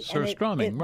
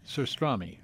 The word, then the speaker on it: Surströmming: And then on the third Thursday in August, it's called, and I'm going to have to get our guests to pronounce it. I think it's Surströmming Day. – Surströmming, right. He's the guy who ran the deli. – Yeah. – Surströmming, right.